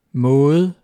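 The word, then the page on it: maade: Danish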